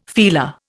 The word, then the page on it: feeler: Indian